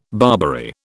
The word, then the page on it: Barbary: British English